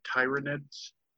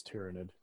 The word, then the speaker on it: Tyranid: Also, yeah, I want an official pronunciation on Tyranid, because I've heard a lot of people call them Tyranids. – It's Tyranid. – Okay, good. – It's absolutely Tyranid.